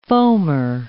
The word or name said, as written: foamer